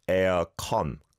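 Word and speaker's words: Aircon (에어컨): You've gone for the or vowel sound, but when we say it in Korean, it's the R vowel sound. Aircon. Con.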